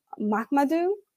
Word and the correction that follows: Mahamadou: Did I butcher it? – Well, Mahamadou.